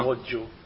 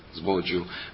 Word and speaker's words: Sbroglio: Et le livre a été écrit par deux Italiens, David Sacchetto et Gianni Sbroglio. Alors je ne sais pas si je prononce bien. – Sbroglio. – Sbroglio, voilà.